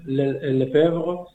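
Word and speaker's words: Lefèvre: Gaúl Lefebvre, no sé si lo pronuncio bien porque yo estudié francés en el instituto y creo que tuve buenas profesoras, pero... – Lo has pronunciado estupendamente.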